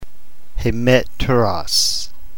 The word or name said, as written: hemeteros